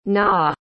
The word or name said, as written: naw